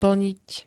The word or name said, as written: plniť